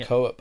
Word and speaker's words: Koepp: Is that how you say his name? – David Koepp. – Koepp, who's written a lot of different things.